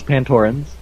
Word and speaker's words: Pantorans: I think three syllables, Pantorans.